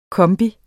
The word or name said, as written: kombi